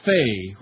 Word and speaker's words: Fay: The Reverend Michael Jude Fay, rhymes with gay,